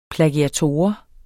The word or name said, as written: plagiator